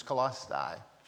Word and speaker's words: Colossae: People pronounce it all different ways, but I think the correct pronunciation is Colossae. Colossae.